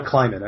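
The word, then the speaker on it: Klieman: Their team, Leidman? Is that how you say it, the head coach? – Kleiman, I believe.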